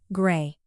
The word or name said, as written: grey